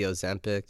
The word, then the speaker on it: Ozempic: Yeah, okay, so back to like the Ozempic, is that how you pronounce it? – Yeah.